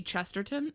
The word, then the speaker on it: Chesterton: Chesterton.